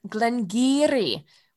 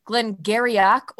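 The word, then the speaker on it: Glen Garioch: Well, speaking of things that are hard to pronounce or are easily mispronounced, I am drinking some Glengarry, which is actually spelled G-A-R-I-O-C-H. So if you're looking at it, you might think it's Glengarryock or Glengarrick.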